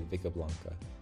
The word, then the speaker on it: Vickeblanka: And uh, by the way, for our listeners, just on the pronunciation of uh, Vickeblanka, so uh, in Japanese, the katakana is Bike Buranka. Uh, and and so just I guess when we say in English, we'll just say Vickeblanka.